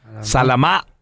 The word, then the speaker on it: Salamá: Con acento en la última A, es una... Salamá, Honduras.